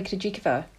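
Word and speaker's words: Krejčíková: Is that am I, am I saying that correctly? Krejcikova. – Yeah, Yeah.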